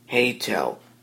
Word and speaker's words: Heytell: And the application I'm going to be focusing on today is called Heytel. The app name is spelled H-E-Y-T-E-L-L.